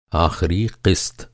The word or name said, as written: आख़िरी